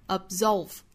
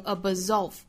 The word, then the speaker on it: absolve: absolve 这个词中间的这个 b 的发音不要爆破十足啊，是应该采取不完全爆破的发音方式，我们要发成 absolve。仅仅就是上下唇碰触一下而已，好，不要发成 absolve, 不要再弹开，应该是 absolve, to absolve, 后面的 debt, 中间的 b 这个字母是不发音的，所以是 debt, to absolve debt。